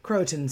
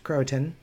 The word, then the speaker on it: Croatoan: And so I'm digging it out, because I actually went through Roanoke Island and Croatoan Sound is, that's how that's pronounced, Croatoan Sound, when my family and I went to Nag's Head this past weekend.